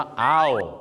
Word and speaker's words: owl: ั่นเองครับนี่ไงหาบเร่ขายของอย่างเช่นนะคุณอาจจะเห็นครับว่า no hawking on the footpath ก็แปลว่าอะไรห้ามนะครับมาแบกกระดินหรือห้ามหาบเร่ขายของอยู่ตามฟุตปาธนั่นเองนะครับต่อไปก็คือนกฮูกครับนกฮูกต้องออกเสียงว่าอ้าวไม่ใช่โอนะครับตอนนี้อุทานอ้าวเหรอออกเสียงว่าโอมาตลอดชีวิต wise as an owl